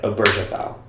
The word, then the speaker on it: Obergefell: That's how you say it. Obergefell. – Obergefell? – Obergefell v. Hodges.